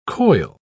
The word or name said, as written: coil